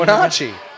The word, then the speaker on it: Wenatchee: We come back with Ryan McKelvey of the how the hell do you pronounce the name of that team? – Wenatchee. The Wenatchee Wild.